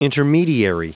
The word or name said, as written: intermediary